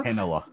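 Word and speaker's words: Heinola: I mean, uh, how do you pronounce it? Ville Henola? Henola? Henola.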